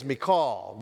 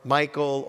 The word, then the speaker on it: Michal: The Hebrew pronunciation is Michal. We can call her Michael or Michelle, but the proper pronunciation is Michal, but you know, we'll call her whatever we want.